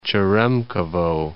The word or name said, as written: Cheremkhovo